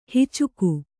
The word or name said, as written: ಹೆಚ್ಚಿಗೆ